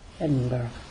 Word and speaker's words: Edinburgh: Bon, je le prononce à la française. Mais pouvait nous prononcer ça à l'écossaise. Comment on dit Edinburgh. – Edinburgh. Moi, je, avec mon accent américain, je dis Edinburgh. Je ne sais pas même.